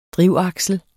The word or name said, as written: drivaksel